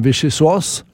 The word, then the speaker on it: vichyssoise: Og når de lærte du det I dine franske dager at det heter vichyssoise? – Vichyssoise.